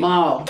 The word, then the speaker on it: Maori: No, but Maori. Maori. – Maori. – Maori. – Oh. Maori. – Maori. Thank you. Maori. That's good.